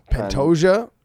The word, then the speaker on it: Pantoja: Pantoja.